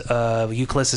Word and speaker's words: Ucles: Euclis is spelled UCLES, and on there I'm also on Twitter too.